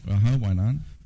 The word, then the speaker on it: Weinan: Now, I don't know how many of our listeners are going to pack their bags and head off to, did you pronounce it Weinan City? – Weinan. – Weinan, and is that the Shanxi province? – Yes, Shanxi.